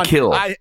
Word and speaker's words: kiln: The Kiln. By the way. – What is it called? – The Kiln. – The Kiln. – Oh, the Kiln? – Yeah. That's how they pronounce it. The Kiln.